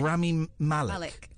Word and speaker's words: Rami Malek: I think it's Rami, isn't it? – Rami Malek. Okay, right. Apparently there's a video going around I've not seen of Rami Malek talking about his favourite things that's quite cringeworthy, so I thought we'd listen to that.